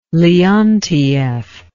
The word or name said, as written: Leontief